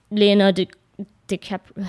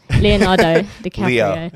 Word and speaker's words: Leonardo DiCaprio: and just having like Leonardo DiCaprio. How do you say his name? – That sounded right. – Leonardo DiCaprio.